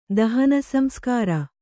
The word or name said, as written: ದಹನಸಂಸ್ಕಾರ